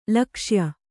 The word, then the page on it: ಲಕ್ಷ್ಯೆ: lakṣye